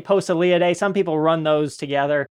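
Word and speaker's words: Poeciliidae: Posillidae. I don't know if I'm saying that right. – I usually say Posillidae.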